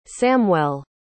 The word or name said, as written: samwell